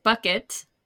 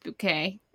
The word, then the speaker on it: Bucket: Oh, and let me read you the climbing the social ladder takes you to hilarious new heights in the company of Hyacinth Bucket. oh, pronounced bouquet I'm so stupid.